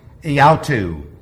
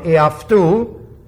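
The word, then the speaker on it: iaftu (ἑαυτοῦ): You've probably heard it pronounced iautu. It's from iaftu.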